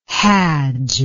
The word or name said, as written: hadj